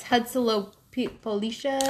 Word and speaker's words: Huitzilopochtli: And then if you want to, it's Huitzilopochtli.